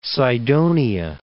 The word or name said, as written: Cydonia